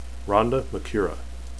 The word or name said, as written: ronde